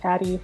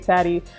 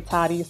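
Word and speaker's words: Tadi: Make sure you check me out, like I said, on the gram at The Only Taddy, T-H-E-O-N-L-Y-T-A-D-I, as well as SoundCloud DJ Taddy. And for the record, my name can be pronounced two different ways. Taddy, most people of West Indian descent say Taddy. Other people here in the States say Toddy. So either way is fine.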